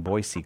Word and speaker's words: Boise: I feel like you should probably have given me credit for pronouncing Boise correctly at some point. I feel like... – We've had this conversation before, and you do pronounce it correctly.